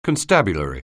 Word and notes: constabulary: anglais

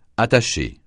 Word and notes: attaché: francais

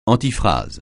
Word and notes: antiphrase: francais

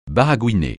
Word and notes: baragouiner: francais

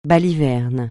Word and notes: balivernes: francais

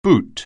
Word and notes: boot: anglais

